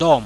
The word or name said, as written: dom